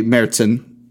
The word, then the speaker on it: Märzen: Oktoberfest is a Märzen. – Okay. – Which I learned is the right way to say that.